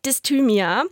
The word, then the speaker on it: Dysthymia: Dysthymia.